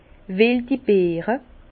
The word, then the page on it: weldi Beere: Bas Rhin; Herrlisheim